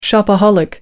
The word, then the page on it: shopaholic: American English